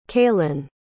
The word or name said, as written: cailin